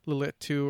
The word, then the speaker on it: Lilitu: And accounts, almost all of the accounts refer to this Lilitu or L-I-L-I-T-U. I don't know how you want to pronounce that. It's not Lilith. I would presume it would be Lilitu or something like that.